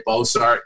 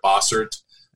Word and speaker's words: Bossert: I oftentimes people will say Bossert instead of Bossert. So it's funny with that name, the first four letters, that's what people used to call me, but not pronounce boss.